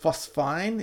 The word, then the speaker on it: phosphine: which I believe is pronounced phosphine. Is that correct, people? – That's correct.